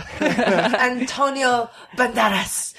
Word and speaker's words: Antonio Banderas: Antonio Banderas.